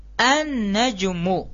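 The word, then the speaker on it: An-najmu (النجم): Langsung an. An-najmu. Ya. An-najmu. An-najmu. Nah, biasanya dikasih tasdid di sini untuk menjelaskan bahwasanya ya, lamnya di sini tidak dinampakkan. Dimasukkan langsung diidgomkan ke nun, ke nunnya. Jadi cara bacanya an-najmu. Ya, an-najmu. Jadi apa? Di sini dibaca dengan diidgom. Karena kenapa? Karena nun ini merupakan huruf syamsiyah. Ya, jadi alif lamnya diidgomkan, dimasukkan ke huruf syamsiyahnya.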